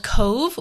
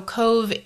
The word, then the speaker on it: Cobh: It's also very close to a a city, not not a city, maybe a town called Cobh, which is spelled C O B H because Irish is very different.